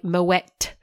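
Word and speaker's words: Moët: And by the way, Moet, you do pronounce the T at the end.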